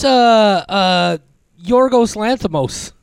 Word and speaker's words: Yorgos Lanthimos: Oh, that's Yorgos Lanthimos is the director of Poor Things.